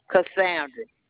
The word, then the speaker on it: Cassandra: Cassandra.